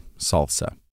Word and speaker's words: SLSA: And researchers at Google also created a software supply chain integrity framework for developers known as SLSA, pronounced salsa.